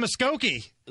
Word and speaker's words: Muskogee: And then Merle Haggard, the Okie from, Okie from Muskogee.